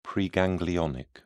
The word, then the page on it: preganglionic: British English